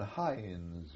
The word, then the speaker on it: Heinz: How do you spell it? – Well, it's German, Heinz, you know.